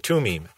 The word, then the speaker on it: Thummim: or the Urim and Thummim. A lot of people mispronounce it, but I think it should be the Urim and the Tumim.